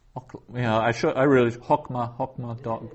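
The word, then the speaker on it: hokma (חָכְמָה): So that's hokma and not hakama.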